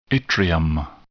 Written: yttrium

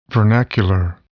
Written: vernacular

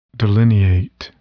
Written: delineate

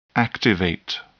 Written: activate

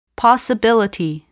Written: possibility

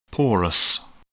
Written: porous

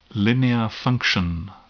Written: linear function

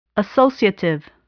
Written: associative

